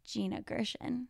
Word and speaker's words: Gina Gershon: So in that scene is when we see Gina Gershon.